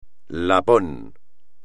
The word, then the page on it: lapón: espagnol